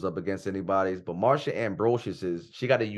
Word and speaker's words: Ambrosius: I thought it was Ambrosia. – Yeah, Ambrosius.